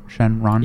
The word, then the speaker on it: Shenron: And it is pronounced Shenron, right? Shenron? – Yeah.